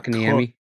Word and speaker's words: Kotkaniemi: Kotkaniemi.